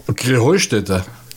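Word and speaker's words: Kreenheinstetten: Krähenheustädte. – Ja, genau, und das ist die Betonung auf dem Heu.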